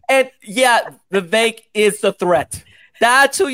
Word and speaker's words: Vivek: Let's just remember that Vivek is actually pronounced Vivek.